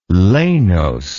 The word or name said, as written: lanose